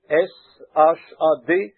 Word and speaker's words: Shad: En effet, le mot hébreu Shad, S. H-A-D, certains le prononcent Shad.